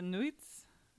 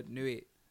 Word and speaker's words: Nuits: So Côte de Nuits. That's probably wrong, you guys. I'm really sorry. – Nuit. In French, you just leave off the last few syllables.